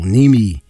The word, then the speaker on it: Niemi: Actually, Niemi, they had 50 shots on goal. Naimi, I guess you pronounce it, had 48 saves as opposed to Kadubin with 27 saves.